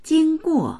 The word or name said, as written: jing1guo4